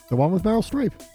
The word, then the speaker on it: Streep: Also, Bart's girlfriend starring Meryl Streep. Gosh. Am I pronouncing that correctly? – Yes. Meryl Streep.